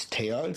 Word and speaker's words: Theo: Like I had a teammate named Theo, but over there they don't pronounce the H, so it's Theo.